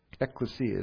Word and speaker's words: ecclesia: Ecclesia. Ecclesia is the right pronunciation.